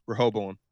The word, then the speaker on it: Rehoboam: How do you say it? – Rehoboam. Rehoboam. Oh, there we go.